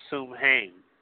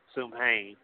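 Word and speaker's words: Samhain: but it's called Sumhain. Sumhain. And the spelling of that, if you had your pen with you, is S-A-M-H-A-I-N. S-A-M-H-A-I-N.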